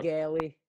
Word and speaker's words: Gaali: I've heard all sorts of renditions. I used to get a Gaali all the time when I was a kid in school. And then it turns out years later, we figured out that actually is the correct pronunciation. So my family has been saying our last name wrong for centuries.